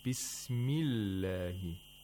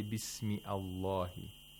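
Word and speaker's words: بسم الله: So that is why we say Bismillahi. We don't say Bismi Allahi.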